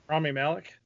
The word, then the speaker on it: Rami Malek: How do you say his name? – Rami Malek.